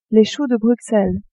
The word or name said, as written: choux de Bruxelles